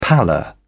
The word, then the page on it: pallor: British English